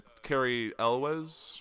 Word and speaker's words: Cary Elwes: Cary Elwes.